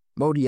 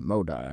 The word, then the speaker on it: Modi: Modi and not Moda, I was corrected in my pronunciation.